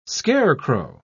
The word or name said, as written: scarecrow